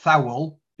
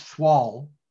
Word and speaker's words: thwall: So it's not thowel, it's thwall.